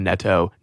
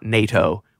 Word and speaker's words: Neto: the Neto, Augustinio Neto, not NATO.